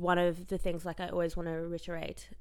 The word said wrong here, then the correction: reiterate: Is that a word? I said it right? – Cool. What did you say? – Reiterate? – Reiterate. – Say it again. – Re. It. – It. – All right. – Erate. – There we go. – Reiterate. – There we go. – Thank you. reiterate. – You were close. I think you had the syllables just like mixed up.